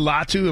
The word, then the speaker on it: Latu: A dude out of UCLA, Latua Latu, am I saying it right? The edge. – You're asking me? – It is Latu, yes.